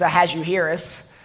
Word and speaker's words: Ahasuerus: The proper way to pronounce his name is Ahasuerus, but that's a bit of a tongue twister, so most speakers prefer to pronounce it Ahasuerus.